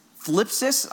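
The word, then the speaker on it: thlipsis: Greek for tribulation is phlipsis. I don't even know how to pronounce it. It's T-H-L. That's weird. We don't say that in English language.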